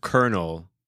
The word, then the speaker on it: colonel: it even says, "Well, we're sorry to break it to you, but it's actually pronounced Colonel." Yes, it makes no Colonel. sense. Yeah, look, so now it's even. Colonel. So CR, no L, null.